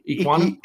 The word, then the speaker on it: Ekwonu: Ekwonu.